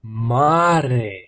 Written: mare

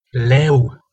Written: leu

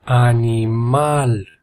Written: animal

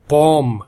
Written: pom

